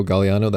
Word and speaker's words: Gagliano: I very stupidly gave all my social media my name, Rico Galliano. That's my handle. And that's a very hard name to spell. I don't know what I was thinking. It's RicoGagliano. There's a silent G in it.